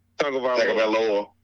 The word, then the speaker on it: Tagovailoa: Somebody say that man's name right. I don't want to just butcher it totally. – Tagovailoa. – Thank you.